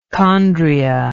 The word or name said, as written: chondria